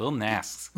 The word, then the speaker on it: Lil Nas X: Is it Lil Nasks? That's correct. Lil Nasx.